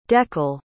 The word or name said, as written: dekel